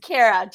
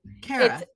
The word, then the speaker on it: Cara: It's Cara, just pronounced C-A-R-A, but Cara. It's a lot better. Cara. Yeah, most people call it Korea, Kahira. Cara works perfectly for me.